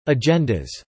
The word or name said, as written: agendas